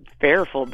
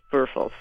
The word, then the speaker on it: Würfel: But then somebody pointed out to me, ironically, Würfel is spelled W-U-R-F-E-L. And of course, there's Alma. Würfel. So I was like, oh, I didn't name it Würfel, but it's Würfel.